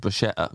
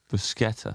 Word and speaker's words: Bruschetta: And I think that you pronounce the C. – Bruschetta.